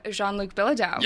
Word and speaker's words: Jean-Luc Bilodeau: Joining me today, I'm so excited to have Jean-Luc Bilodeau.